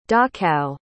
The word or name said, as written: dachau